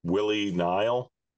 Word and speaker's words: Willie Nile: Willie Nile, am I pronouncing that right? – That's right.